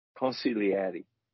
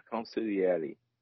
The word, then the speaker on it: consigliere: It's consigliere. – I can't. – You don't pronounce the G. – Yeah. – Yeah, consigliere.